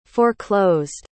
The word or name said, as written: foreclosed